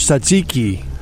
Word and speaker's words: tzatziki: The answer is tzatziki.